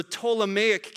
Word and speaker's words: Ptolemaic: And then the one in the south was the Ptolemaic kingdom. That has a P, a silent P at the beginning, if you're wondering.